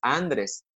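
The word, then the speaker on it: Andres: Realmente el apellido es Andrés, o sea, sin acento pero pues la gente lo pronuncia como si fuera el nombre Andrés.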